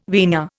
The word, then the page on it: vina: Indian